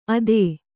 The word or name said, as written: ಅತ್ತೆ